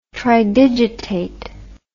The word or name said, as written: tridigitate